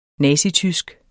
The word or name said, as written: nazitysk